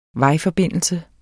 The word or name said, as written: vejforbindelse